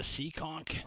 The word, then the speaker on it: Seekonk: It's spelled like you see a conch. – Yeah, it's S-E-E-K-O-N-K.